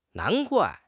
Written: nan2 guai4

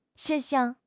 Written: 事项

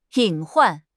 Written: 隐患

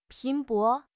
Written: 拼搏

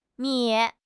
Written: mi3

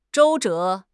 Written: 周折

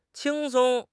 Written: qing1 song1

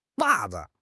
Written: wa4 zi5

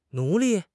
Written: 奴隶

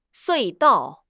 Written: 隧道

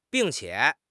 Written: bing4 qie3

